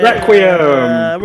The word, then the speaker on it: Requiem: Spell it. – R E Q U I E M. – Yay! 1 point. – Requiem. – Requiem.